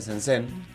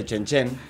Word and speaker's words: Shenzhen: Y le decía Shenzhen, y resulta que no se dice Shenzhen, se dice Shenzhen, no sé por qué la Z se pronuncia como CH.